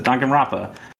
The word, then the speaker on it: Danganronpa: If you like Japanese games, though, Danganronpa. That's how you pronounce it. Danganronpa.